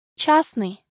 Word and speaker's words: частный: We pronounce it like частный, and we don't pronounce the consonant Т in the middle of the word. – But it should still be written.